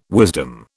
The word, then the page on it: wisdom: British English